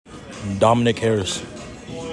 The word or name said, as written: DOMINIC HARRIS